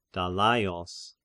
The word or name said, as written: Dalaios